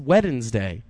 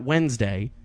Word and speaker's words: Wednesday: It's pronounced Wednesday. Not Wednesday.